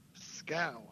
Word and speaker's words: Skow: Is it Skow or Skow? – Skow. – Skow. I knew I was going to butcher that.